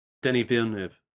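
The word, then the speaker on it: Denis Villeneuve: So, you know, I think for Denny, how do you say his name? – Denny Villeneuve.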